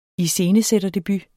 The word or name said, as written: iscenesætterdebut